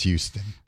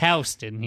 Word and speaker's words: Houston: It's pronounced Houston. – This is something we can tell the hayseeds out there. It's Houston here.